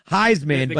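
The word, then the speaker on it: Highsman: Heisman, but high is spelled H-I-G-H.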